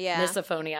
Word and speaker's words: misophonia: I sort of talked with the ladies before this episode of, obviously there is the disorder called, how do you pronounce it? Misophonia? – Misophonia.